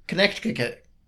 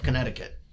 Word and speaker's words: Connecticut: Connecticut. – What? – Connecticut. But you said Connecticut. – Well, that's how it's spelled. – Oh. – Did you not notice it's got an extra C in there? – No.